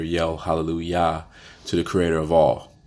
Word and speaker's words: Hallelujah: So that's why when you um, when you look at it, and it's, and it's spelled Hallelujah, spelled with a J, but it's not said with a J. It's Hallelujah.